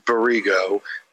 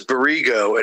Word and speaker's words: Barigault: So the guy that made this, like I said, his name is Berigo, but it's not spelled B-A-R-I-G-O. His name is Berigo, and he's French. It's spelled B-A-R-I-G-A-U-L-T.